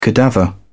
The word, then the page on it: cadaver: British English